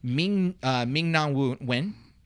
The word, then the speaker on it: Ming-Na Wen: Then you also have Jane Lynch, Wanda Sykes, Weird Al, Nicole Byer, Ming-Na Wen, I believe is how you pronounce her name.